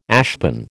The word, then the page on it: ashpan: American English